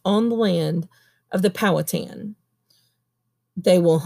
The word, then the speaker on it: Powhatan: Powhatan.